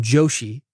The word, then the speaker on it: Joshi: The host, Shrikant Joshi, and I'm going to spell it for you. It's an Indian guy. S-H-R-I-K-A-N-T. That's the first name. Shrikant. Last name, Joshi. J-O-S-H-I.